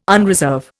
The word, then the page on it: unreserve: Indian